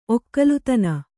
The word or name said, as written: ಒಗ್ಗಾಲಿತನ